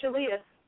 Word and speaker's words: Shaleyah: And Shalia. – One more time? – Shalia. – Spell that for me. – S-H-A-L-E-Y-A-H.